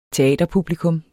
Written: teaterpublikum

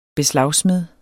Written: BESLAGSMEDE

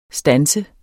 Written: STANSER

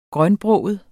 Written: grønbroget

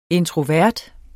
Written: introvert

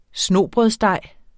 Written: snobrødsdej

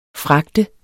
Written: fragte

